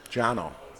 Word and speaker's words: Cano: Jono. – Jono. Yeah. The C is pronounced as J.